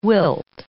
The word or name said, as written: wilt